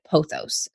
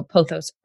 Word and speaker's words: pothos: And some people pronounce it pothos. So pothos or pothos, kind of like tomato, tomato situation.